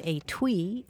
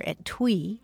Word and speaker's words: etui: If you do enough crossword puzzles, you're sure to come across the word etui or etui. It's spelled E-T-U-I, and it means a small case for sewing needles or other little items.